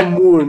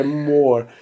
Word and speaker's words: Moore: O Gary Moore, tem que pronunciar direito, Gary, né? Gary Moore. – Ele fala Moore, né? Mas é Moore.